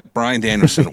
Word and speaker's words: Brian Danielson: However, at the end, Brian Danielson. Wow. Yeah, I said it right, and I thought I said it wrong. – No, you're right.